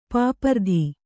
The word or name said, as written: ಪಾಪರತ